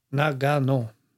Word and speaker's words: Nagano: Eller man ska uttala på japanska, så ska man inte ha någon betoning, vet jag. Nagano.